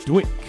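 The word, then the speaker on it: DWYCK: Dwick, as it's correctly pronounced, not Dwight, as I've been saying for many years.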